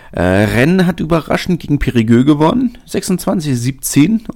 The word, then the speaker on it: Périgueux: Und ja, das spricht man tatsächlich so aus. Ich war auch überrascht. War in, der letzten, in den letzten Folgen nicht so ganz sicher, aber ja, Périgueux ist richtig.